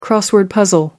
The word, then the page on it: crossword puzzle: British English